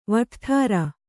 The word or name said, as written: ವಟ್ಠರ